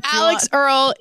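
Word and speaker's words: Alix Earle: Alex Earl is the Gen Z Alex Cooper. I think you said that. That's how you say it.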